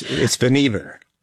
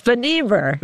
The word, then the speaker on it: Vannevar: You can tell us how to pronounce it here. – It's Vannevar. – It's Vannevar. – But everybody called him Van. I guess we everybody can. That made it a lot easier. I was putting the accent on the wrong syllable there.